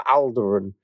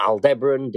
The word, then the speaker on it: Aldebaran: But the point about this is that we've got the Hyades up, which has its bright star Aldebaran, or Aldebaran, depending on how you want to pronounce it, sort of thing.